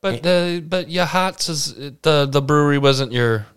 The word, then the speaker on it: Yachats: Beautiful Yahats, spelled Yachats. – You'd never know how to pronounce it unless you went there and someone told you.